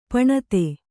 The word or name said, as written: ಪಣತಿ